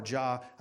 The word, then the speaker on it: Yah: It's one of the only words that is actually in all capital in the King James Bible, and it's Y-A-H. And you pronounce it Yah or Jah. I don't think God cares either way, but it's the name of God that you cry out in the face of your enemies.